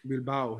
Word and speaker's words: Bilbao: And what they're trying to do there, the Munich Stadium, Amsterdam, I'm going to guess Bilbao. – Bilbao, yes. Yeah. You said it right.